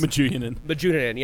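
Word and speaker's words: Medunjanin: They did lose Menduan. Guy, his names are fucking terrible. Terrible to pronounce. – Majunin, yeah. Thank you.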